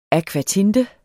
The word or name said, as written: akvatinte